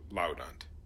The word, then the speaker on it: laudant: Now, but because when you put those together, the A becomes a short A, because you've got kind of a double consonant after it, so laudant.